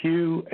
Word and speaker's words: Hugh: It's Hugh, H-U-G-H.